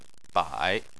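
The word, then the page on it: bai: tone 3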